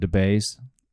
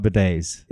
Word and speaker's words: bidet: We also have a debate about debays. Did I say that right? – I think that's what we should use. – Yeah, I think so too. You did not say it right. – I didn't get anywhere close. We had a great debate about bidets.